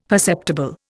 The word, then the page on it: perceptible: Indian